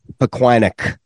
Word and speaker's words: Pequannock: How do you say that? Pequannock? – Yeah, Pequannock. Yeah.